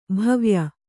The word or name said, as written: ಭವ್ಯ